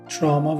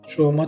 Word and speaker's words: تروما: هر سه کلمه تروما، تراما و تراما صحیح هستند. تراما تلفظ آمریکایی، تروما تلفظ بریتیش و تراما تلفظ لاتین هست.